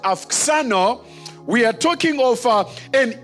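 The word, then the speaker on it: auxano: it is the word afxano. Afxano. Afxano. Now, the spelling of it is A-U-X-A-N-O. A-U-X-A-N-O. But when you pronounce it in Greek, it's afxano.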